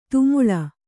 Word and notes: ತೆಮಳ್: temaḷ; Kannada

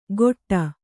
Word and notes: ಗೊಟ್ಟ: goṭṭa

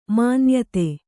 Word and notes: ಮಾನ್ಯತೆ: mānyate

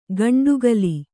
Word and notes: ಗಂಡುಗಲಿ: gaṇḍugali

